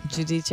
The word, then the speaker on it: Giudice: Giudice.